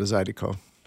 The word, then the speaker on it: Zydeco: Yeah, and then just the pronunciation, saying it quickly, it sounded more like a D than an R, so it went from Zydeco to Zydeco.